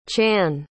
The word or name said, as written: chann